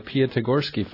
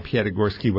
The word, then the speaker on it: Piatigorsky: I also understand that you're part of the Piatigorsky Foundation. Am I pronouncing that correctly? – Yes. Yeah. Okay. Gregor Piatigorsky was my grandfather.